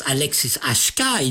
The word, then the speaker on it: Alexis HK: The singer-songwriter Alexis HK, pronounced Alexis HK in French, is immediately identifiable through his deep, melancholy voice.